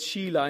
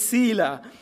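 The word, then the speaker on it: Selah: I was just sharing with Janet earlier on, because in Romania you pronounce this differently, and you call it Sheila in Romania, where we've got Sheila's here, names of a person, but here it's Sila.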